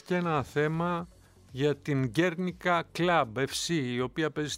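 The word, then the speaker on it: Guernica: Αν δεν ξέρουμε την Γκέρνικα, ξέρουμε σίγουρα το έργο που το λένε πολύ Γκουέρνικα, αλλά Γκέρνικα είναι η σωστή προφορά.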